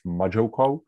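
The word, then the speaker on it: MuJoCo: So I think you'd pronounce it as Majoko. Mama joins context, yeah, Majoko, probably.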